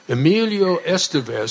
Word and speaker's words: Emilio Estevez: Emilio Estevez, see, I said that right.